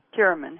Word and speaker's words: Curamin: Curamin. How do you spell it? – C-U-R-A-M-I-N.